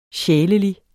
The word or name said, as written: sjælelig